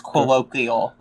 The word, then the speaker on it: colloquial: Colloquium? – It's colloquial. Like some you say something colloquial.